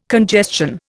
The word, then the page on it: congestion: Indian